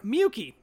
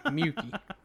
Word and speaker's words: Miyuki: He just wanted to bring back food for Miyuki, this girl. – How you say it? – Miyuki. – Miyuki. – Miyuki. – Okay. – Almost like mucus, but Miyuki. – Miyuki. Okay. Miyuki.